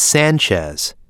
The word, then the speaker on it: Sanchez: It's Sanchez. S-A-N-C-H-E-Z. – My last name is Patterson, with one T. P-A-T-E-R-S-O-N.